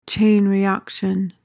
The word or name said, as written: chain reaction